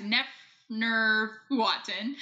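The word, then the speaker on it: Neferneferuaten: I did really look up the pronunciation because I am very respectful of this stuff, but I still couldn't get it. Yeah, it's Neferne Furuaten,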